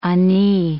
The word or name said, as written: ani